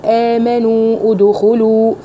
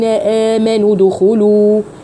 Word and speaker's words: آمنوا ادخلوا: فالدال الساكنة مع الواو الساكنة حصل التقاء ساكنين، فهيحصل ثقل في النطق، فهقول آمنوا ادخلوا فهيحصل ثقل. طب وبعدين اعمل ايه عشان اتخلص من الثقل ده؟ هحذف حرف المد الاول، فهقول يا أيها الذين آمنوا دخلوا، آمنوا دو، آمنوا دخوا، ودخلت على طول على الدال، تمام؟